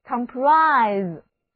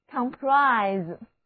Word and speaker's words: comprise: 字母 I 在这里读它的本音 I，而字母 S 要读作浊辅音 Z，重音第二个音节。一起来念两遍吧， comprise comprise。